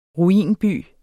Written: ruinby

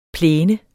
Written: plæne

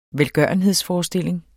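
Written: velgørenhedsforestilling

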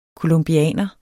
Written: colombianer

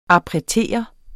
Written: appretere

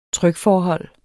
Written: trykforhold

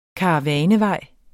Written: karavanevej

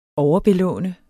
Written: overbelåne

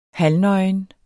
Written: halvnøgen

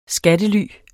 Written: skattely

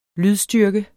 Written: lydstyrke

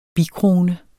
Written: bikrone

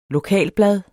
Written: lokalblad

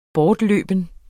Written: bortløben

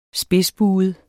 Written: spidsbuet

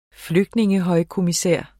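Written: flygtningehøjkommissær

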